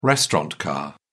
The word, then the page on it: restaurant car: British English